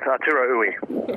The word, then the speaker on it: Arturo Ui: The Resistible Rise of Arturo Ui. Am I getting the pronunciation of that name right? Correct, Arturo Ui.